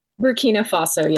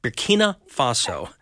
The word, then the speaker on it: Burkina Faso: Burkina Faso, yes. – Burkina. I always pronounce it wrong. Burkina Faso. So, well, anyway, thanks for joining us this morning on Mornings with Carmen.